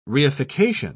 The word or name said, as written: reification